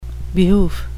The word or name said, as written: behoof